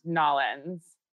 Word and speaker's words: New Orleans: I'm broadcasting live from New Orleans. – I think it's pronounced Nollins.